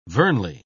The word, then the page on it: vernally: British English